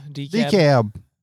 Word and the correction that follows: DeKalb: DeKalb. – DeKalb.